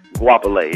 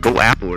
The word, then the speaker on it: Guapale: That's how you say her name? – Guapale, yeah. – I was trying to figure out. I was thinking like Go Apple or something, you know? – Yeah.